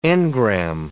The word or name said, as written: engram